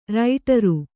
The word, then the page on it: ರೈಟರು: kannada